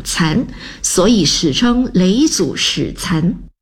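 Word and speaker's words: 嫘祖: 雷祖。你不要以为我不会读那个字哦，左边女字旁，右边是疲累的累，这个字读作雷。哼，你八成以为我会读成罗是吧？田螺的螺是虫字旁，雷祖的雷是女字旁，不错嘛。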